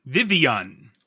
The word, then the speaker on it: Vivillon: Vivillon? – No. Dino? – Vivillon? – I don't know. Vivion. – What? – Vivion. – Wow. – So V-I-V-E-E-A-A-N, if you're doing phonetically pronouncing. Vivion. There's no L sound.